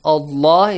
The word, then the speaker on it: Allah: Allah is pronounced with a heavy sound.